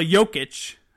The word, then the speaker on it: Jokic: Him, It's it's Jokic.